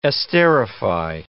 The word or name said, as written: esterify